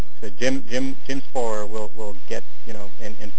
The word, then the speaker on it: Spohrer: And also Google is a good start right now, that if you Google Jim Sporer, and his name is spelled S-P-O-H-R-E-R, Sporer,